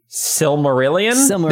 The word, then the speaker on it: Silmarillion: Silmarillion.